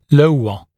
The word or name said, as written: lower